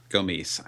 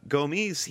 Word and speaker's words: Gomis: either it's Christian Benteke or it's Befetambi Gomis. I wonder if I said that right that time. – Well, possible. Only Twitter will tell us. But yeah, Gomis, there you go. You got it.